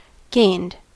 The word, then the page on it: gained: American English